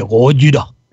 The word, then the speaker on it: Gojira: no se dice Godzilla, se dice Gojira.